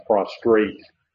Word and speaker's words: prostrate: We're not coming down before a throne and prostrating ourselves before the throne, but rather, I didn't say that right, I just thought I said the word wrong. It's prostrate. I left the R out.